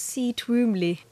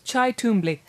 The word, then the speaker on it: Cy Twombly: Yes, I always thought it was C. Twombly. – It's Chai Twombly.